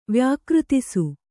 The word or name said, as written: ವ್ಯಾಕೃತಿಸು